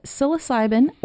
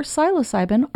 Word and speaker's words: psilocybin: And, fun fact, in researching this, I found out that psilocybin or psilocybin are both acceptable pronunciations.